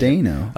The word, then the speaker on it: Steno: Number two is Nicholas Steno. I think that's how you say it. – Steno.